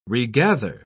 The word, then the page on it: regather: British English